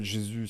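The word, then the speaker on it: Jesús: Je lui dis, bah, tu m'appelles Jésus si tu as envie de l'utiliser à la française et tu m'appelles Jesus si tu as envie de le prononcer à l'espagnol.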